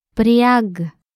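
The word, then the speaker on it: Priag (бряг): Priag. Priag. Priag. – Notice how does not pronounce the G at the end as a G, but more like a K.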